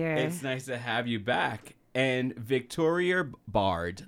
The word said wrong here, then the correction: Baird: Baird. All right, Baird. I mispronounced it last time, so I'm trying to get it right this time, y'all.